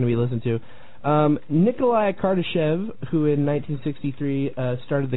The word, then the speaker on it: Kardashev: The Kardashev scale? – Kardashev sounds good to me. It looks like it's Russian, so I think... – It is Russian. – That'd probably be the right pronunciation.